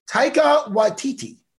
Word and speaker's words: Taika Waititi: And the father's played by Taika Waititi.